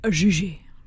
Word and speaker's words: Gigi: Gigi. Which is how they pronounce it in the movie and on the Broadway show. – Yeah. – Because it takes place in France.